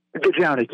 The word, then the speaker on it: Gajownik: Ronnie Gajownik. Is that how you pronounce her name? – Gajownik, yes.